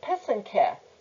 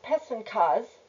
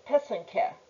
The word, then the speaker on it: pysanky: It's spelled P-Y-S-A-N-K-A. The plural for many Ukrainian Easter eggs is pysanke, spelled P-Y-S-A-N-K-Y. It's not pysankas, nor pysanki, nor pronounced any other way. It's pysanke.